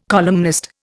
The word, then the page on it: columnist: Indian